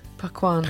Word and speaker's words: Paquin: Starring, this is another tricky one pronunciation-wise, is it Anna Paquin? Paquin? – Paquin.